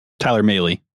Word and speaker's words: Mahle: Mal? – Tyler Mahle.